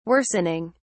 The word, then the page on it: worsening: British English